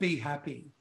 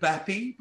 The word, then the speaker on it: Bhapi: It's spelled, a lot of people calling it Bappy because it's spelled B-H-A-P-I. So depending on how you phonetically speak, like for me, it was automatically Be Happy, but I get Bappy as well. But that's okay. I don't mind.